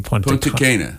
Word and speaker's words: Punta Cana: Punta Cana.